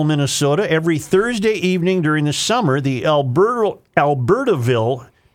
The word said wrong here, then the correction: Albertville: Why am I mispronouncing Albert? – Albertville. I'm sorry.